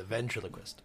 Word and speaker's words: ventriloquist: Yeah, the ventriloquist. – Is that what it is? It's not Van, it's Ven? – It's Ven. Not Van. – V-E-N.